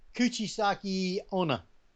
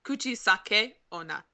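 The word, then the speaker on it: Kuchisake-onna: Kuchisaki Ona. – So close. – So close. Listen to this. So close. – Yeah. Okay. It's actually pronounced Kuchisake Ona.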